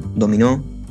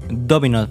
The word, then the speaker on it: Domino: Domino, Dominó, como le quiera decir. – Domino, tengo entendido que se pronuncia.